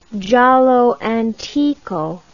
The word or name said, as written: giallo antico